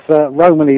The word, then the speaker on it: Romany: Is that how I pronounce that? Romany? – Yeah, I think so, yes. I mean, it's Romany is certainly the correct pronunciation.